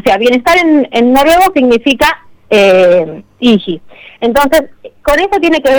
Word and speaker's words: hygge: Sí, H-Y-G-G-E. – Ah, hijito, no, Iji. Bueno, ¿qué quiere decir? – Yo la pronuncio así, cada uno la pronuncia, tiene una pronunciación rara, entonces viste, hay algunos que la pronuncian de otra manera, otros de otra, yo le digo Iji. – Deletréela de vuelta, a ver. – H-Y-G-G-E.